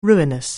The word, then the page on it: ruinous: anglais